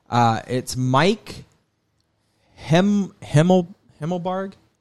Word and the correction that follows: Himmelgarn: I knew I would do that. I can't pronounce it correctly. But it's Mike Himmelgarn. That's what it was, Himmelgarn.